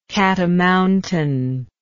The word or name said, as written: cat-o'-mountain